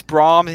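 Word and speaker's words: Brahms: Is it Brahm? – It is Brahm indeed.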